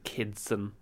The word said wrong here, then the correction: Kitson: it's not it's kitson my Kitson.